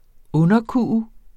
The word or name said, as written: underkue